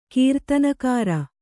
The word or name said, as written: ಕೀರ್ತನಗಾರ